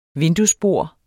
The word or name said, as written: vinduesbord